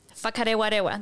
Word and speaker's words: Whakarewarewa: Whakarewarewa, that's the right pronunciation.